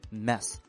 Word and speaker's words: mess: 这第一个单词呢，它叫 mess，注意发音上它是一个小口的 a mess。那么对于这个单词，还有另外一个单词，它是大口的 a，叫 mass。